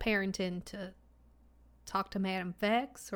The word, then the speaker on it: Vex: Yeah, Vex, V-E-X, Madam Vex.